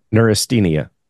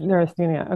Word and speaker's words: neurasthenia: Do you know how to say that correctly? – Yeah, neurasthenia. That's it. – It is neurasthenia.